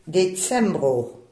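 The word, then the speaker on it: decembro: Et décembre. – Très bien prononcé. Décembre, le C se prononce ts, hein, ts pour nous.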